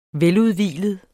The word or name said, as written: veludhvilet